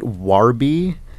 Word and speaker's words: Warby: I always try and say it Warby, so it's clear that it's spelled W-A-R. – Yeah. – Because I sometimes worry if I say Warby, it'll be like W-O-R.